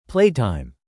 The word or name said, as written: playtime